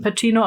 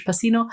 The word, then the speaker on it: Pacino: Ja, also ich glaube Pacino, auf Italienisch spricht man es eben Pacino, auf Amerikanisch Pacino.